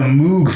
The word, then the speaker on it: Moog: And it ends with a Moog synthesizer solo that's pretty wild, which I've been calling a Moog for the longest time, but nope, it's actually pronounced Moog.